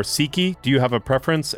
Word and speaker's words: Sikhi: It's with a short I, not Sikhi. A long I.